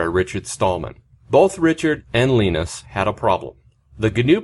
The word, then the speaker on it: Linus: Now, Linus Torvalds speaks Swedish, so the I is actually pronounced E, so it's Linus.